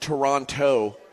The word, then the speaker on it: Toronto: But Toronto, I've never heard.